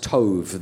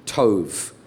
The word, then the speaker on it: Tob: The next thing it says, that when he is driven out, he goes to the land of Tob, T-O-B. It's in Hebrew, it's pronounced Tov. The B is a V, Tov.